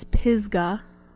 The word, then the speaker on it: Pisgah: So then they travel on from out of the desert into Pisgah, which GE likes to call Pigza. – Pigza. – But it's Pigza. – It's Pisgah. Pisgah. No? – No. – Okay. – I'm not. I refuse. – I do not accept your pronunciation.